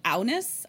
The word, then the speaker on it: Agnes: And now that I know that Agnes is pronounced Aunus, I'm like, oh, I don't know.